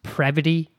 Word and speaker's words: Previty: And for the local listeners, it is pronounced Previty.